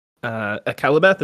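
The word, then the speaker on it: Akallabêth: Yeah, and when, you know, when they sailed to Middle Earth already, I was like, I read the Akelabeth, is that what it's, how you pronounce it? – Yeah, the fall of Numenor.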